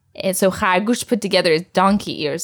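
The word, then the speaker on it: khargush (خرگوش): And an animal that rhymes with that is khargush, and that means rabbit. Khargush.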